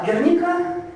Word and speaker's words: Герника: Герника – это именно правильное ударение, потому что это испанский топоним, это название маленького баскского города, и сложившееся в России слово «Герника» – просто некая ошибка.